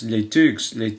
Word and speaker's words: Les Thugs: And the guy was saying, it's Les Tugs. And I was going, what's he saying, Les Tugs? And I said, and then when I found out, you know, Les Tugs is spelled T-H-U-G, Les Thugs,